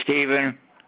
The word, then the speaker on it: Stephen: Yeah, Stephen, with the P-H, S-T-E-P-H-E-N. Last name is Ish. It's spelled O. E-S-C-H.